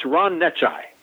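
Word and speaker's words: Necciai: Well, the way you pronounce his last name, it's Ron Necci. – Ron Necci. – Right. It's N-E-C-C-I-A-I.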